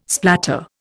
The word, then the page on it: splatter: Indian